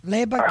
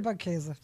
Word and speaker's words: Leberkäse: Leberkäse. – Leberkäse. – I hope I got it right. – Leberkäse.